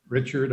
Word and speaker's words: Richard: Richard, R-I-C-H-A-R-D. Coleman is spelled C-O-L-E-M-A-N.